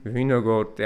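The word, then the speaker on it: Vingegaard: jak Wingegaard. Winogord, jak Brian Holm właściwie po duńsku e, wymawia.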